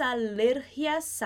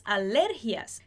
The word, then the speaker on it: alergia: Se a gente perceber aqui, a palavrinha alergia muda a sílaba mais forte entre o português e o espanhol. Em português a gente fala alergia, em espanhol a gente fala alergia. Alergia, português, alergia em espanhol.